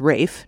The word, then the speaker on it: Ralph: Yeah, I mean, he's another one who can get you in trouble with pronunciation because his first name looks like Ralph, but it's Rafe, just like Rafe Fiennes, the actor. So it's Rafe Vaughan Williams.